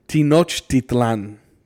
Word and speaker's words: Tenochtitlan: All right, so yeah, now the final push towards Tenochtitlan, dude. – Yeah. See, and I have it pronounced as Tenochtitlan.